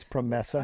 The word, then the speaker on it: PROMESA: I tried to get a pronunciation. I assume it's PROMESA. – I think you're right.